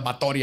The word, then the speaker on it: Báthory: La condesa Erzbet Batori de Exed, porque le se, se pronuncia Báthory aparentemente, nació en Nibrator, en Hungría, en lo que ahora se conoce como Eslovaquia y Rumania, el 7 de agosto de 1560.